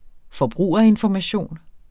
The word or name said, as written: forbrugerinformation